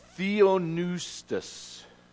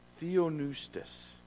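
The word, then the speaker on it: theopneustos: It's this word. Theonoustos. The P is silent. Theonoustos.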